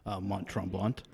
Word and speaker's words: Mont Tremblant: And I'm going to butcher the name, but it's Mont Tremblant? – Yeah. – Is that pretty close? – I certainly say Mont Tremblant.